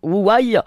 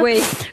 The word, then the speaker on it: Huawei: Comment on prononce ? Huawei. – Oui. – Voilà. Merci pour votre aide en chinois. – Huawei.